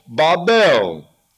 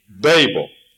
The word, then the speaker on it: Babel: And so it's pronounced ba or ba, and the accent is on the last syllable, Babel. But I won't say that very much, even in a Presbyterian church. We'll say Babel.